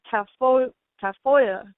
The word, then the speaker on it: Tafolla: it's pronounced a little differently, like Tafoya.